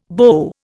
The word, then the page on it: beau: Indian